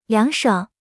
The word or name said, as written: 凉爽